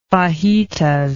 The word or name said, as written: fajitas